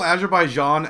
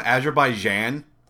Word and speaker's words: Azerbaijan: Did you just call Azerbaijan Azerbaijan? – Either pronunciation is technically correct.